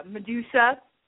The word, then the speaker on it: Madusa: We had a lot of fun with the driver of Medusa. So that's the pink truck, and it's spelled M-A-D-U-S-A, like made in the USA.